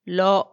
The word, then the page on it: lo: Macuiltianguis